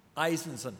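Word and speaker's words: Izenzon: Eisenson.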